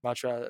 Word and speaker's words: Matier: Uh, so we'll take uh, Nolan Allan and uh, and Jack Matier, Matier, Matra, his name is pronounced, instead.